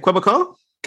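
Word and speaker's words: Québécois: Quebecois. – Quebecois.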